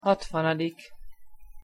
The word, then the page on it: hatvanadik: Hungarian